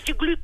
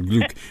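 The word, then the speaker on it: Glück: E o que eu acho muito interessante na poesia de Louise Gluck é o facto de ela cruzar, ou Louise Gluck, como eu também já tenho ouvido dizer.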